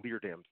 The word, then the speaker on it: Leerdam: I misspoke and said Kelvin Leerdam's name wrong. I called him Litera, but it's Leerdam.